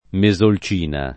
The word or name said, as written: Mesolcina